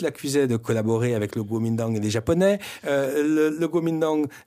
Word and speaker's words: Kuomintang: Kuomintang.